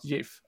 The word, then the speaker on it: GIF: They are wrong, it's a soft G, pronounced GIF, end of story.